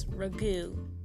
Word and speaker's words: ragout: And a ragu, it's actually like spelt out R-A-G-O-U-T, so it's ragout. But I feel like it's ragu, you know? Because sometimes the T is silent, you know, and you, yeah.